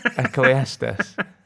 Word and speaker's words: Ecclesiastes: Is that a train time to Ecclesiastes? What? – So it's pronounced Ecclesiastes. – Ecclesiastes? – Yes. – Ecclesiastes. – Jordan's writing the phonetic pronunciation. – Ecclesiastes as in John Cleese.